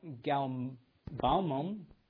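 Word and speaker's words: galbanum: The Lord said to Moses, take sweet spices, stakti, anakunk, galbalmum, that's how you pronounce it, sweet spices with pure frankincense.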